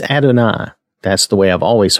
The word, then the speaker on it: Adonai: That looks like Adonai. I don't know why that is. I've never heard it pronounced that way. I've always heard it pronounced Adonai.